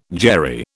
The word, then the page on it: Jerry: British English